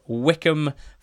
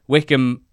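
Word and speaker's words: Wycombe: Tom, who is our Morecambe fan from Peru on the marvellous NTT20 squad, called this the Silent B Derby. Both Wickham and Morecambe having a rogue B in there.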